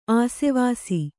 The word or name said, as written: ಆಶ್ವಾಸ